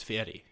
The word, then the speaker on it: Fieri: It's not Fieri. It's Fieri.